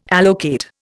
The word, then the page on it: allocate: Indian